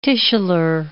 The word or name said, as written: tissular